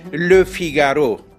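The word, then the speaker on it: Le Figaro: فرانسوی ها فیگارو را با ادای حرف تعریف آن لو فیگارو تلفظ می کنند.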